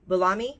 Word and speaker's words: Boulomai: it's the common origin called the Boulami. I'll spell it B-O-U-L-O-M-A-I, Boulami.